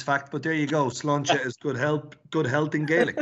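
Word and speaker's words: sláinte: And Chris, just before you go, it's interesting how you pronounce that, because the first word means good health for us in Irish as well, Slancha. But interesting how you put the two of them together in Slangeva.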